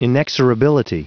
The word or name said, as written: inexorability